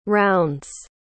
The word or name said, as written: rounds